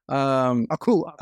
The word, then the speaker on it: Aku: Every time I hear his voice, I always think of Abu from is it Abu? Samurai Jack? – Oh. – Am I saying it wrong? – Aku. Aku. Aku. Abu is the monkey from Aladdin.